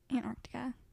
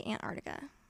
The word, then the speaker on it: Antarctica: I say Antarctica. Like, I know there's a C in there, you guys, but who says it like that?